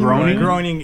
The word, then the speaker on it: Groening: Because Matt, how do you say his name? Matt Groening. – Groening. – His original concept for a cartoon was bunny people, and so it was kind of a nod to that old.